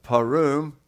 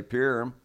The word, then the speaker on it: Purim: It's pronounced Purim, and that's even with the southern dialect. In Southwest Virginia, we would say Purim.